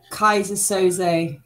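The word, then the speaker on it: Keyser Söze: Yeah, Kaiser Soze.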